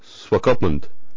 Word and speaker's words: Swakopmund: Swakopmund.